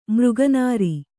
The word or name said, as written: ಮೃಗನಾರಿ